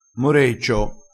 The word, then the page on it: moreccio: dialettale